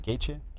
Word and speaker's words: Gaethje: I have Eddie Alvarez and Justin Gaethje. How do you say Gaethje? – Gaethje.